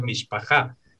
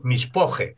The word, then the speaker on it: mishpoje: Recién mencionabas lo de familia, que en hebreo es mishpajá y en yiddish es mishpoje.